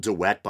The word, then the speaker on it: de Wette: I believe it was suggested in the beginning of the 19th century by biblical scholar Wilhelm de Wet, uh, spelled de Wette, but I think it's pronounced de Wet.